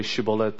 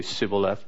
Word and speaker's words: Shibboleth: And so the code word was Shibboleth. But these guys could not say Shibboleth, they could only say Sibboleth. And so they tested them, and all the people who couldn't say Shibboleth, but they say Sibboleth.